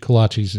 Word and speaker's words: kolache: And I can tell you, if you eat kolaches in Caldwell, of course, I understand for all my Czech friends listening, it's pronounced Kolache, that you will have a good time.